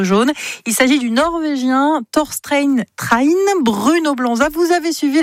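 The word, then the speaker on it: Torstein Træen: Thorstein Train, vous l'avez bien prononcé effectivement.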